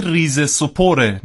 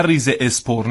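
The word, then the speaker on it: Rizespor: سپور خب سپور و اسم این تیم باشگاهی ریز سپوره نه ریز اسپور نه ریز اسپورت همه دارن در مورد صحبت میکنن اما هیچ که حداقل این واژه باشگاه رو درست تلفظ نمیکنه بله. – ریز سپور ورزش به ترکی استانبولی میشه سپور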